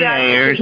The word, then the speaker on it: Aethyr: This is that's chapter 23, supplement B in the Book of Revelation series, and it's spelled A-E-T-H-Y-R, air.